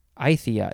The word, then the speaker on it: Aythya: Aythia is spelled A-Y-T-H-Y-A. Aythia.